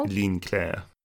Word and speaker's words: Ligne Claire: I say Ligne Claire, but that's probably not how you pronounce it at all. – Ligne Claire. – Of course, it's French, isn't it? – Yeah. – Of course, it's not pronounced like it's written.